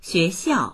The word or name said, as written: xue2xiao4